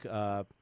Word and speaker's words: Ntilikina: Ntilikina. – The T is silent.